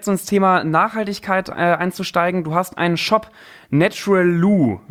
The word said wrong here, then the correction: Naturalu: wenn ich das richtig ausspreche. – Nee, Naturalu.